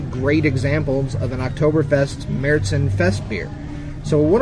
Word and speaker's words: Märzen: And usually you'll find on the label the title Oktoberfest, or Märzen, M-A-R-Z-E-N. That's pronounced Märzen.